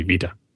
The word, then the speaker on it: Vita: And the pronunciation is Vita. – Living la Vita Loca. – Yeah. Kind of sounds like Viva.